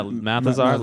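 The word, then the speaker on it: Mathesar: Yeah, Malthazar, leader of the Thermians.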